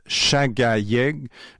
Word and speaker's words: Shaghayegh: H-A-N-T-E accent aigu E. Donc l'autrice se nomme Chagayeg Moadzami. Chagayeg, ça s'écrit S-H-A-G-H-A Y-E-G-H. Et Moadzami, ça s'écrit M-O-A-Z-Z-A-M-I.